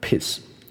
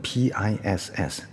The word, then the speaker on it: peace: Anyway，回到那个梗，那个梗它本身叫和平使者啊，对，和平使者，peacemaker，peace 和平，p e a c e，那个 e a 啊，因为两个母音的关系，所以我们应该把它延长一点，所以我们是念 peace，OK，peace，但是另外一个人开他玩笑，把它念成 peace。我再念一次，peace 跟 piss，right？所以很明显的，前面第一个是长音，后面的那个是短音，它会念成 p i s s，就是尿尿啊，就是本人本身那个是和平制造者这样子，OK？